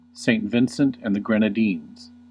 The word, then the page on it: Saint Vincent and the Grenadines: IPA: /seɪnt ˈvɪnt.sənt ænd ðə ˌɡɹɛn.əˈdiːnz/